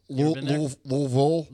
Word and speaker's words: Louisville: Louisville. – Louisville. – Louisville. That's how you say it.